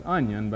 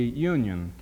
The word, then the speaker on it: Union: He's a native of Monroe and the great county whose name is pronounced by some as Onion, but actually it's supposed to be Union County in North Carolina.